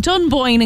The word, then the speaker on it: Dunboyne: So she's very delighted that a big American singer is saying Dunboyne in County Meath as she sings.